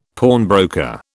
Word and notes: pawnbroker: British English

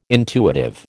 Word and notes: intuitive: American English

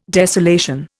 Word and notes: desolation: Indian

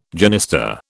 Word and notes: genista: British English

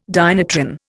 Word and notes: dynatron: Indian

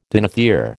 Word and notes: dinothere: American English

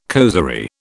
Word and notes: causerie: British English

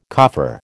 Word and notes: coffer: American English

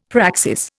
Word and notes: praxis: Indian

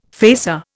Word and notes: facer: Indian

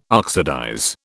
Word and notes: oxidize: British English

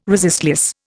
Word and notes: resistless: Indian

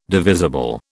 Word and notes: divisible: British English

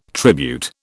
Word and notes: tribute: British English

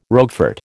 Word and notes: Roquefort: American English

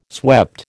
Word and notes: swept: American English

